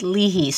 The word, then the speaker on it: Leahy: The family says Leahy, but it's an Irish name and it should be pronounced Leahy. So we pronounce it wrong, too.